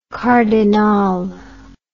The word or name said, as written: cardinale